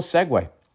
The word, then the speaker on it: Segway: Can somebody phonetically write out how to pronounce? According to my American English, it's still Segway.